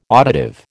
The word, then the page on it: auditive: American English